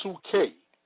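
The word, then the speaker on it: psuche (ψυχή): The word soul in the Greek is pronounced psuche, from which we derive the word psyche, dealing with the mind.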